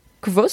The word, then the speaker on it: Kvothe: Parce que l'histoire, c'est celle d'un héros qui s'appelle Kvothe, c'est compliqué à prononcer, ça s'écrit K-V-O-T-H-E, donc faites-le mieux que moi, mais okay.